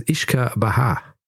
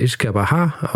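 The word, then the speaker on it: uisce beatha: Ähm, das irische äh, heißt übrigens Ishka Baha. Also das ist äh, Ishka Baha, aus dem wurde dann Whisky.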